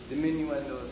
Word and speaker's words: diminuendo: It's abbreviated in music as dim, D-I-M, period. Diminuendo is how they say it in Italy. Diminuendo is how we say it.